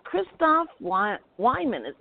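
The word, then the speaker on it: Christoff Weihman: Our guest today is Christoph Wyman. Did I pronounce that correct? – Oh, yeah.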